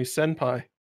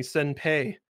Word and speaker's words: Senpai: Because I have Siri calls me Senpai. She had originally called me Senpei. And I was like, no, that's not how you pronounce that word.